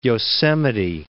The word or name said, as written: Yosemite Falls